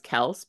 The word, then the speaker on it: Kelce: So like, Taylor Swift and her relationship with Travis Kelce, who actually I'm told that his last name is pronounced Kels, but he just doesn't like, they don't correct people.